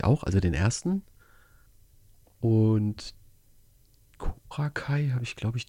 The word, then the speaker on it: Cobra Kai: Cobra Kai. Spricht man die eigentlich so aus? – Yes. – Cobra Kai. Yes. So wie Kai aus der Kiste.